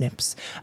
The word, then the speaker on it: SNPs: um, or you know SNPs, which are pronounced SNPs.